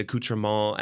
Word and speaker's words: accoutrement: I say that word, but I don't say it right. Accoutrement. – The French way, yeah.